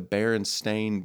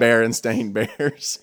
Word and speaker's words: Berenstain: If we found out that the moon landing was false, I would be more shocked that the Berenstain Bears is pronounced right. Berenstain Bears yeah. – and not Berenstain.